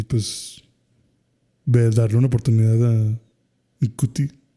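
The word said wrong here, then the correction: Ncuti: Chuti. – Chuti se pronuncia.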